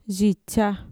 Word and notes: yitxa: Mixtec

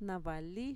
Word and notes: na-vali: Mixtec

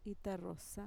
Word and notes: itarrosa: Mixtec

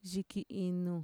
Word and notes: yikiinu: Mixtec

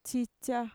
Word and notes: txitxa: Mixtec

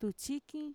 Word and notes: tutxikin: Mixtec

